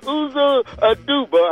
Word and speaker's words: Uzo Aduba: Congratulations to actress Uzo Aduba.